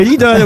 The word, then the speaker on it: Lidl: Il n'y a pas de O, c'est l'idole. – Oui, Lidl.